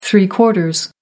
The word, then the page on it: three-quarters: American English